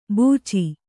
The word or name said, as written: ಬೂಚಿ